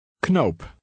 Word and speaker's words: knoop: In Dutch, the K is pronounced. – Knoop.